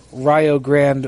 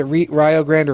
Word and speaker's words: Rio Grande: He was a head coach in the D League for the Rio Grande Vipers. Is it Rio Grande or Rio Grande? – Rio. Yeah, we've been having pronunciation problems today.